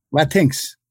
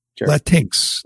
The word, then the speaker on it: Latinx: Latinx. – Latinx.